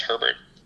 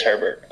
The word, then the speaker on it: Herbert: Am I saying his name right? Herbert? Hebert? – I think it's Herbert. – It is Herbert.